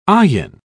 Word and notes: ayin: British English

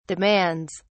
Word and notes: demands: British English

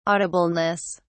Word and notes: audibleness: British English

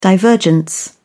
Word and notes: divergence: British English

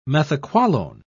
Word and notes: methaqualone: British English

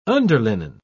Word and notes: underlinen: British English

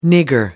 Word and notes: nigger: American English

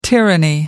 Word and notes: tyranny: American English

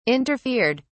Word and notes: interfered: British English

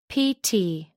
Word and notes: pt: American English

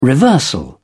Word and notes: reversal: British English

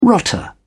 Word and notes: rotter: British English

